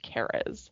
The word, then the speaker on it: Cares: So, the man who started it, his name is Pastor Mark Cares it's spelled like Cares, but it's pronounced Cares.